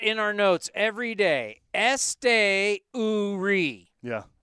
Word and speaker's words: Esteury: She's she gave me the Esteury. And then now you can see it how phonetically it's Este Uri. Yeah. Este, what's capital O O? Ooh. Ooh. Esteury.